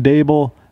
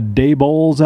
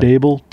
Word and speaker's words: Daboll: Also, PSA, you are saying Daboll absolutely correctly. There are a lot of Daybowls out there, a lot of Dayballs out there. It rhymes with table. – Yeah. – Daboll, table.